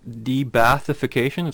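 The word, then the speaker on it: de-Baathification: An interesting one that you put, too, you talked about the blanket de-bathification. Is that how I'd pronounce that? – Yeah.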